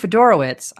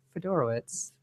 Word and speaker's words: Fedorowicz: Now, CJ Fedorowicz, I finally got this name right on my first try. – You did it! – I say it right every time I'm doing my notes, and then I get on the show and I'm like, bleh, bleh. Fedorowicz, twice now.